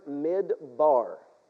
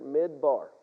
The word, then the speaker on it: midbar: The word desert or the word wilderness, when it's translated into the Hebrew, it's the word midbar. The word midbar, okay? So here's the word desert, all right? If you back up in the Hebrew, we would pronounce it midbar, all right? Midbar.